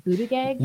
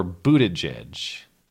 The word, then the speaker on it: Buttigieg: Buttigieg? – Legitimately, I've heard Buttigieg or Buttigieg.